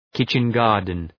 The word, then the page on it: kitchen garden: ‘kıtʃən,gɑ:rdən